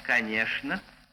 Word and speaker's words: конечно: В живом же языке эта группа «ЧН» перешла в «ШН». И в живых словах произносится в Москве «ШН». «Конечно», «скучно», «молочное», «булочное», «пустяшный».